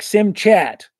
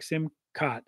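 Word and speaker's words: Simchat: Simca, like sim chat. You know, when I say that word, I say sim chat. What kind of word is that? But they pronounce it like simcat or whatever of the Torah, a celebration of the Torah.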